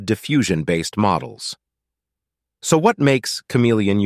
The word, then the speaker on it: CM3leon: Today we are talking about Meta's new generative model for text and images, CM3leon, pronounced like chameleon.